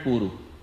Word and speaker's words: Puru: This is a different Puru. And Puru has a short U. Puru has both U short. Puru has first U long and second U short.